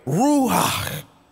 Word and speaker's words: Ruach: In the Hebrew, that word Spirit is translated as Ruach. Ruach. No, I'm not mispronouncing it. I'm saying it the right way. Ruach. Ruach.